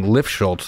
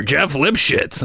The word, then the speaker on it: Lifschultz: and I was so humiliated about Ed McMahon mispronouncing Lifschultz three times. Every time I won, it was like, "Your challenger, Jeff Lipschitz," and I was like, "Oh," and I wasn't strong enough of a comic to like not let that stuff bother me.